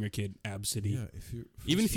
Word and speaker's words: Abcde: It was ABCDE. So she spelt it. And someone laughed at her, and the kid, it's pronounced Absidy.